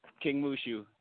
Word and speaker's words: King Mushu: King Mushu, just okay. Like the dragon from Mulan.